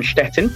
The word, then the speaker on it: Szczecin: She was born in Szczecin. I pronounced it correctly, to a Polish mother and a Bulgarian father.